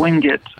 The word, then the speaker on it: Wingate: I think they prefer it sort of like an I instead of the A. – Wingate. – So okay. – It's Wingate.